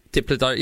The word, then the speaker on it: Diplodocus: I think it's a Diplodocus. Is that how you say it? – Diplodocus.